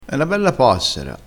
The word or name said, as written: passera